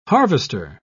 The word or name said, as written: harvester